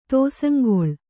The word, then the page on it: ತೋಸಂಗೊಳ್: kannada